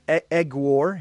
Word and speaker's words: Aguiar: Is that how he's pronounced it? He says, Aguiar.